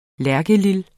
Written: lærkelil

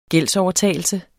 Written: gældsovertagelse

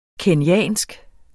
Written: kenyansk

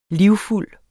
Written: livfuld

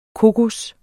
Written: kokos

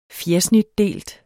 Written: fjersnitdelt

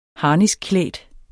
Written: harniskklædt

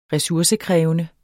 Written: resursekrævende

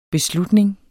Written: beslutning

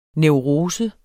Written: neurose

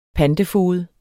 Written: pantefoged

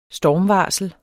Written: stormvarsel

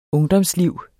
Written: ungdomsliv

